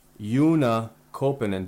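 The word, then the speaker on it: Joona Koppanen: Yuna Kopanen. Did I say that right? – Yeah.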